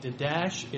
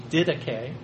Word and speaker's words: Didache: you pronounce that, it looks like Didache, it's Didache. Didache.